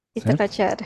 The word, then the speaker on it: Itacoatiara: Itacoatiara, certo? sim. – Ufa, finalmente consegui, no ar eu consegui falar certo.